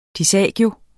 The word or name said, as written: disagio